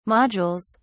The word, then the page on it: modules: British English